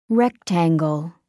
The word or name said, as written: rectangle